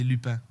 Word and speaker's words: Lupin: Is that how you say it? – We say Lupin. – Lupin. – In French. Lupin. – You is that good? – Say it right. It's Lupin.